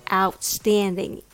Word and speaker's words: outstanding: That's what outstanding is all about. Now let's talk about pronunciation. The key to nailing this word is stressing the second syllable stand. Give it a little extra push, stand.